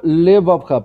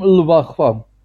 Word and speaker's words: левавха: И заметьте, это шва, и тем не менее мы его произносим левавха. Мы не говорим лвахва. Это, да, мы да, должны произнести здесь шва, короткое «э», сказать левавха, «ле».